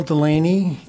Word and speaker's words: Delaney: Well, my name is Bill Delaney, and I'm a resident of Holiday, and I'm a Rocky Mountain Power customer, and I am a net meter customer.